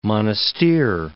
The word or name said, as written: Monastir